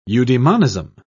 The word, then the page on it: eudaemonism: British English